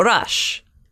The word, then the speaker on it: Roch: How do you spell it again? – R-O-C-H. – Oh, Rush. It's a French word. It's so Saint you'll probably Roche? Say Roche, but Rush means rock in French.